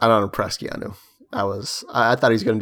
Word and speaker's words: Keanu: And apparently, according to Wikipedia, it's pronounced Keanu.